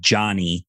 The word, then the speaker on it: Joni: It's J-O-N-I. Erickson is E-A-R-E-C-K-S-O-N. She actually pronounces her first name Johnny in honor of her dad.